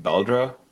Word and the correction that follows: Beldre: I'm just going to call Beldra. – Beldre. – I haven't listened to the graphic audio one for this, so I don't know how it's pronounced.